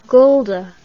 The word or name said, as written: gulda